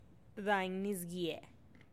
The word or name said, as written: dainnisgie